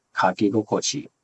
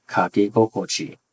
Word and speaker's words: kakigokochi: The first word is kakigokochi, kakigokochi, kakigokochi, and it means how the pen feels when it writes.